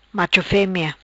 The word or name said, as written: Macho femea